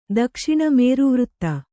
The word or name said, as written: ದಕ್ಷಿಣ